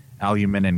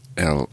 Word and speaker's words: aluminium: Killer taught me last week how to crush aluminum in cans. – Aluminium. – I have to say, that is an interesting pronunciation for aluminum. – It's aluminium.